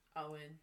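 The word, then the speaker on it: Owyn: Owen.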